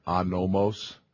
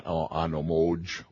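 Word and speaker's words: anomos (ἄνομος): The meaning of anomos, that's the Greek there, anomos, not anomoj, or you can't even pronounce MWJ in English, can you?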